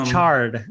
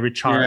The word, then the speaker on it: Richarde: Am I saying your name correctly? Because I see the E in the end. – Yeah, so it's and I'm always thinking that to say. – It's Richarde.